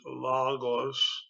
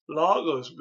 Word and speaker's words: logos: People say logos. It's all right, but the better trans, uh, pronunciation is logos because there's two different O's uh, in that word. One is omicron and one is omega. So it's logos, not uh, uh logos, not logos.